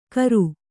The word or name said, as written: ಕರ